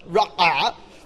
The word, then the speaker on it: ra'ah: Ra'ah. Okay, that's how you say it. It's ra'ah.